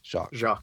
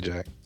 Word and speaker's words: Jacques: It's Jacques? Am I saying that right? Jacques. Okay. God, that's so hard. I don't know. I have trouble with that. I keep wanting to say Jack.